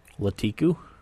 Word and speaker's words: Lakitu: Did I pronounce that right? – Latiku? I thought Lakitu. – I always thought it was Lakitu. – Yeah. – That's what I thought. – Let's go with Lakitu.